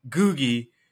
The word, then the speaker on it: Ngugi: Yeah, it's like Ngugi, so the N is silent. – We have Joseph Ngugi.